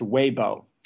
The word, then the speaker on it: Weibo: It's pronounced Weibo.